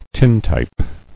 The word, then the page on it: Tintype: tin·type